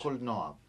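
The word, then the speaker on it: Kolnoa: Come si pronuncia? – Col Noir, l'accento alla fine.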